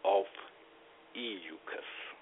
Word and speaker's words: Ophiuchus: Well, you know, dealing with when we add Scythians or Pisces, I mean, Ophuscus, or another pronunciation is Ophiuchus. Ophiuchus. I prefer Ophiuchus.